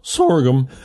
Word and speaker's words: sorghum: There was one word you mispronounced. – What's that? – It's a sorghum. – Sorghum. – Yeah. Whenever you have to reference. Say it that way.